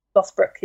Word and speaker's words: Lothbrok: which is Ragnar Lothbrok. Am I pronouncing that correctly? – Lothbrok, yeah.